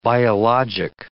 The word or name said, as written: biologic